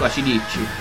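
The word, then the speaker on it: AC/DC: In verità andrebbe detto ACDC. – Ah, ACDC. – Con l'accento appunto sulla D, sulla I dopo la D. – Come dice Francesco, acidicci.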